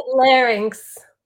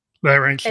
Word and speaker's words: Larynx: You want to let people know we've got your link up, the Wild Larynx? How do you pronounce that? – Larynx. – Larynx, the Wild Larynx. – It's like your throat.